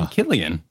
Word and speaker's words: Cillian: According to a guy who went how to pronounce on YouTube and did one of those, he said Cillian.